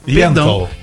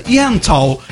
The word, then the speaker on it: Yentl: Ó, a Alessandra Maestrini, ela tá em peça, ela tem peça, que é Ente. Out in Concert. – Yentl. – Eu vou fazer com pronúncia Yentl.